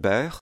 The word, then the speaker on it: beurre: Well, often, of course, those are silent R's. It depends, okay. But there are some R's that you do hear at the end, and those are usually Beurre, so beurre.